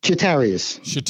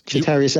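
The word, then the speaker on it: Chatarius: Chitarius. Chitarius Atwell.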